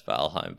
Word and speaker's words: Valheim: You're actually like trying to, you're complicating it when you can just pronounce it as American as possible and you'll be pronouncing Valheim. It right. – No, it has a weird spelling. – Yeah, it's Valheim, but it's just a little survival game, you know?